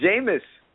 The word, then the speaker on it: Jameis: Is it Jameis? – Yeah, yeah. Jameis.